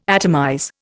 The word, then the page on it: atomize: Indian